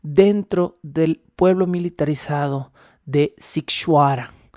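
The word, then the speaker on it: Sighișoara: Eh, escríbase totalmente distinto a lo que acabo de pronunciar, lo estoy pronunciando fonéticamente, pero se escribe eh, eh para quien lo quiera investigar, Sig, S-I-G-H-I-S con cedilla o ara, pero se pronuncia Sikshuara.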